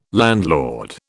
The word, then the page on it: landlord: British English